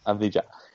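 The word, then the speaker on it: Avdija: Abdiya.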